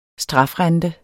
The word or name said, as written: strafrente